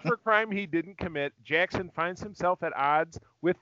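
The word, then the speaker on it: Jaxxon: But this one actually touches on the Big Green Rabbit Jackson, which cracks me up. It's, even though it's spelled J-A-X-X-O-N, I have a son named Jackson, so that always that cracks me up.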